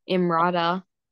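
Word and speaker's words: Emrata: then I've got another pop culture that Pete Davidson apparently is dating Imrata. – Yeah, that's how you say her name.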